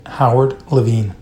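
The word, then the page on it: Howard Levine: English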